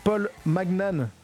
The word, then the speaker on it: Paul Magnan: Et après Talisco, on continue avec Paul Magnan, ça se prononce bien comme ça, je me suis pas planté. – Ça y ressemble, tout à fait.